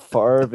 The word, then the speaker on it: Favre: You know, with like Brett Favre, it's spelled V-R-E, but you pronounce it R-V-E.